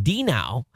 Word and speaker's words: Dino: So Dino, oh, it's pronounced D-Now. Exactly like the Flintstones dog.